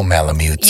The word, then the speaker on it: Malamutes: But a lot of people, hundreds of people come to watch The beautiful Malamutes.